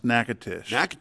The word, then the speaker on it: Natchitoches: So we've been through there a couple times, and they will quickly correct you that it's pronounced Natchitoches.